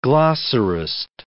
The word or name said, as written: glossarist